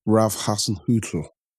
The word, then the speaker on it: Ralf Hasenhüttl: We've got to move on to Ralf Hasenhüttl.